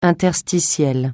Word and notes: interstitiel: francais

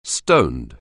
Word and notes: stoned: anglais

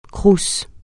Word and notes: CROUS: francais

